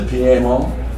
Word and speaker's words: Piedmont: In the PMO area, this is how I pronounce Piedmont. That's how we pronounce it in Georgia.